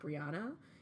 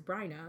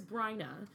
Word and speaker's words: Bryna: And yes, my name is Bryna, spelled B-R-I-A-N-A. It's not Brianna. It is not Brianna. It is Bryna.